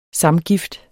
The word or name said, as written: samgift